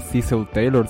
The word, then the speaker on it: Cecil Taylor: En la guitarra tiene cierto toque de Cecil Taylor, o Cecil Taylor, si prefieren la pronunciación norteamericana.